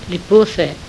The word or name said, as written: pose